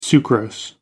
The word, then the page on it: sucrose: American English